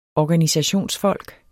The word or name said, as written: organisationsfolk